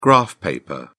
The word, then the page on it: graph paper: British English